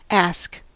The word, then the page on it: ask: English